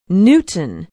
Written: newton

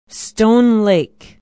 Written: stone lake

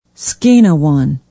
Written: skanawan

